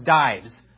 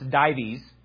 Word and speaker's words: Dives: I thought it was Dives at first. It turns out it's not. It's Dives is the correct pronunciation of it.